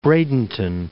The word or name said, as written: Bradenton